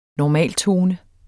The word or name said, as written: normaltone